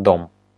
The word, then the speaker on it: DOM: Мы произносим как дом.